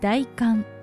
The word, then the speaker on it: 大寒: こちらも字の通り大きく寒いと書いて大寒と読みます。